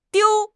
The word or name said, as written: diu1